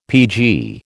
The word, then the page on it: PG: American English